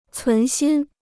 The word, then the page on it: 存心: cún xīn